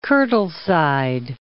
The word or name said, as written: Douglas of Kirtleside